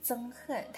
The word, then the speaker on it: 憎恨: 那如果你有认真的准备过高考，一定有听过这个词憎恨，它的发音是在第一声。